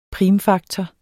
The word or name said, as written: primfaktor